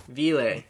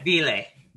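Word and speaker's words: Wille: And I guess by, you know, Vila or Vili, or I don't know how to pronounce it, because when I say it like that, it sounds like Zila. But I feel like it's another German word. And I don't know. Vile. Okay. – Vile. – Vile. Thank you.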